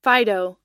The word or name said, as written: fido